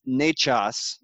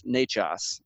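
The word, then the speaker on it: Nečas: So it's Martin and it's Nechas. It's actually spelled N-E-C-A-S. So it looks like Necas, but it's Nechas.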